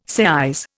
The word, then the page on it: seise: Indian